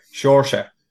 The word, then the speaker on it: Saoirse: I just think of the shore, you know, Saoirse. And I know how weird that sounds, but that is the correct pronunciation of it.